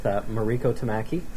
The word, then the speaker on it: Mariko Tamaki: I'm sitting here with Mariko Tamaki. – No. – Did I get the pronounce right? – Yeah, you did great.